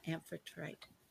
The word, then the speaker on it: Amphitrite: Yeah, Amphitrite.